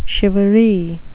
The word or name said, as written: shivaree